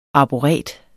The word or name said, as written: arboret